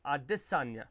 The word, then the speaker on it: Adesanya: I want to say it right. Israel Adesanya. I was a little too extravagant there. Adesanya.